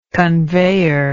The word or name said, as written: conveyor